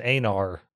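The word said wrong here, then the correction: Aenar: I was mixing up Andorian and and uh, but it's I looked up the pronunciation. I don't know if they always I don't know if they say Enar in this, but it's supposed to be pronounced Enar.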